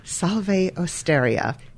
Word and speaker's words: Salve Osteria: Well, I'm glad you guys figured out how to say it, because I ask our waitress, is it pronounced Salve Osteria or Salve Osteria? And she goes, however you want to say it. And I was like, that's not helpful. – I think people pronounce it a lot of different ways, and they hear it a lot of different ways. So, yes, we will provide the correct pronunciation, Salve Osteria.